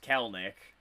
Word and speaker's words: Kelenic: So, Jared Kelenic, which apparently his name is pronounced Kelnick. Like, okay, I didn't know that.